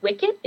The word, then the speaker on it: WCIT: So we pronounce it WICIT.